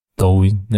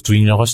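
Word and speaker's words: 더우인 (抖音): 더우인. 네. 뭐 발음 따라서 두인이라고 할 수도 있겠네.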